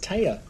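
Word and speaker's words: Téa: Her name is pronounced Taya rather than T, as like I thought.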